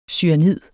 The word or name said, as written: cyanid